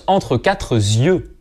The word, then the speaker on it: entre quatre yeux: « Entre quatre yeux », on le prononce avec un S, mais ce S, on l'écrit pas.